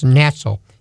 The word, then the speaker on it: natzal: There's a Hebrew word pronounced natzal, N-A-T-Z-A-L.